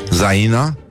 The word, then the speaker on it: Zaina: În oglindă Zaina? Așa se pronunță? Da, da.